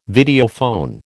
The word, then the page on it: Videophone: American English